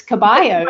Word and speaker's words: Caballo: It's Caballo. It's she. You don't pronounce the L's.